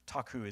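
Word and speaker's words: takhu (ταχύ): So the word quickly here is, it's takhu. Takhu is how you say that in the Greek.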